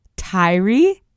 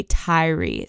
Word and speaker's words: Tyre: So I had heard several news outlets, as well as literally looked up how to pronounce the name Tyree, but I have been pronouncing it Tyre. All online, people were saying Tyre Nichols, so I assumed that was how to pronounce it, but it's actually Tyree. So I apologize for that mispronunciation, and we'll be fixing it going forward, obviously.